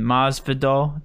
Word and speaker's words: Masvidal: And Masvidal, did I say that right? – Yep.